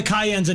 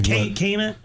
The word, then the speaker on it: Cayenne: No, the Cayenne's another one of the... – It's like Cayman? – Cayenne is how you say it.